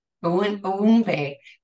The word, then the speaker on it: Unuigbe: All right, it's pronounced as Unuigbe. – Unuigbe. Dr. Cynthia Unuigbe.